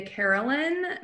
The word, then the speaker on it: Carolan: All my social media is just under at Julia Carolan.